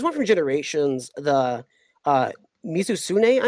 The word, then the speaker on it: Mizutsune: Mizutsune is. is. one of the uh, I, I have fought a, Misusune, a Mizutsune, and um, it's one of the most beautiful monsters in the series.